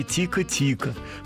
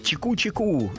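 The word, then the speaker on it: Tico-Tico: В мировом музыкальном обиходе закрепилось название «Тика-тика» с ударением на первый слог. Но по правилам бразильского диалекта правильнее говорить «чику-чику» с ударением на второй слог.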